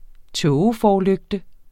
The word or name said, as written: tågeforlygte